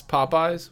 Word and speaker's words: Popeyes: Popeyes. How is it pronounced? – Popeyes. – All right, sure. – Like the guy with the spinach.